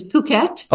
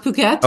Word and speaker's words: Phuket: It's exactly, I understand, it's Phuket. – Okay, that's how it's pronounced. Phuket.